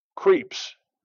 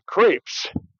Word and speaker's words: crepes: Oh, we went to the Point, and she had some crepes. No, excuse me, crepes.